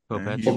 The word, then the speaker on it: Kopech: Is it Kopech or Kopech? – Kopech.